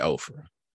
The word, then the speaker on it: Ophrah: I heard it pronounced a couple of different ways. We're going to say Ophrah, which belonged to Joash, the Abizarite.